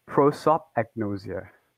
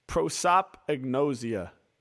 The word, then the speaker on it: Prosopagnosia: So pronounce it one more time. – Prosopagnosia. – Prosopagnosia. – It's almost like two words together. – Yeah, like, prosop, agnosia. Okay, dope.